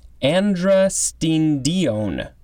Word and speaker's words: androstenedione: But looking at the pronunciation guide, I think it is androstenedione. A-N-D-R-O-S-T-E-N-E-D-I-O-N-E. And it looks like it can also be pronounced androstenedione. So slightly different emphasis.